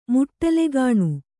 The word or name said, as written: ಮುಟ್ಟಲುಗಾಣು